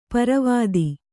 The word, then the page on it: ಪರ್ವತ: parvata; Kannada